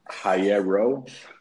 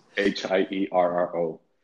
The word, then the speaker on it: Hierro: So Instagram, it's first and last name at, it's so hard to pronounce my last name in English, at Julian. – I can't do it. – Hierro. And that's Hierro.